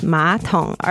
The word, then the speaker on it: 马桶: which is 马桶, and it's third tone and third tone. But when pronounced, the third tone becomes second tone. So it's 马桶, 二声跟三声, 马桶, toilet in Chinese.